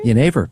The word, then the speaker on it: Genever: And then you're going to have to help me out with the pronunciation of the Dutch liqueur, Genever? Genever? – Genever, yeah. – Genever, exactly.